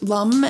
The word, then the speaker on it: Lam: It's spelled L A M, pronounced lum as the note is.